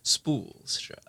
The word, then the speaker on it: Spoolstra: No, it's spool, like a spool of yarn, right. Like straw. But just the way you're like, Spoolstra.